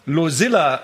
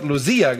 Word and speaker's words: Losilla: Weil ich mal Losilla anstatt Losia gesagt habe, ja.